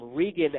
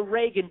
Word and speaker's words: Regan: Also, my name, Regan Allen Ashton, just Regan Ashton probably, but no Reagan bullshit here. It's a long E guy. We ain't Reaganing over here.